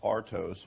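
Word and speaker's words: artos (ἄρτος): pronounced artos,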